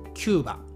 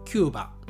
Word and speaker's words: キューバ: きたアメリカ合衆国やメキシコ合衆国と同じでほとんどの日本人はキューバと言います。キューバ。共和国までは使いません。キューバ。えー、ということはですね、英語のキューバと同じ発音で同じ言い方ということですね。